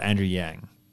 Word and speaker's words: Andrew Yang: I want to just say we were talking about we had this discussion a while ago about Andrew Yang.